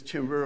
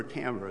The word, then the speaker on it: timbre: Now we have to start talking about something that no one's mentioned, which is timbre or timbre. It's pronounced both ways.